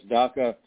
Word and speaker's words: DACA: Of course, DACA rhymes with CACA, just saying.